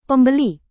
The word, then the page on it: pembeli: Indonesian